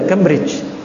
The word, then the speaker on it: Cambridge: Dan mereka di Cambridge, ya, Bacanya betul ya, Cambridge. Iya.